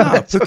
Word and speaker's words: Phuket: Bruce, how do you say the word P-H-U-K? P-H-U-K-E-T.